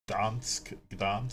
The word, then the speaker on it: Gdansk: It's gonna be called the Gdansk, silent G. – Gdansk.